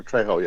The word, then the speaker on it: Trejo: But I'm sorry, I pronounced it wrong. Trejo, yes. – Well, whatever. Maybe you're saying it wrong as well. – No, you're right. You're right. Yeah.